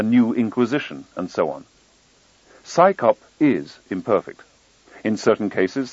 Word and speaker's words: CSICOP: Its initials, CSICOP, are pronounced as the acronym PSICOP, as if it's an organization of scientists performing a police function.